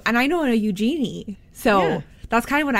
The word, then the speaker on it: Eugenie: It's not Eugenie, it's Eugenie.